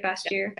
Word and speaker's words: Bastyr: Bastyr.